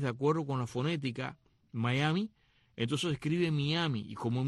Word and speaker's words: Mayaimi: Miami, que se escribe M-A-Y-A-I-M-I, Miami, Miami. Y ahí es donde sale justamente,